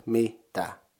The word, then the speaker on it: mitä: Combining them you get mi tä. Mitä.